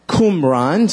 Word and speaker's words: Qumran: Qumran.